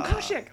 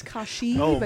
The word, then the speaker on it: Kashyyyk: What if someone's like making like little, I'm going to say it wrong, Keshek. – Oh, Kashyyyk. Little Kashyyyk. Close. Very close. – I think that's a cereal my grandmother is. – That's Kashyyyk, but it's okay.